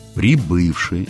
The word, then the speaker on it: прибывший: У действительных причастий прошедшего времени акцент смещается на корень, то есть на второй слог. «Прибыл», но «прибывший». Это просто запомнить надо, и все.